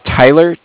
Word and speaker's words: Tyler: My name's Tyler, T-Y-L-E-R, and I'm actually calling with Cardiff. – T-Y-L-E-R.